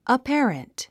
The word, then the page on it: apparent: əpǽrənt; アッペアレント